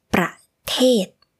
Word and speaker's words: ประเทศ: this word pronunciation is a little similar to ประเทศ which means country But it is different. For the word type, it is ประเภท. The second syllable is p, p, with the p, ประเภท. But for the country, it is ประเทศ. The second syllable starts with the sound like t in English.